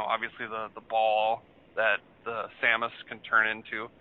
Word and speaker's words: Samus: Samus, yep, yeah.